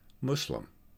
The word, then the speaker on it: Muslim: And Muslim has morphed to a more proper pronunciation, Muslim.